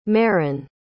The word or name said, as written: merryn